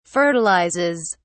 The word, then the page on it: fertilizes: British English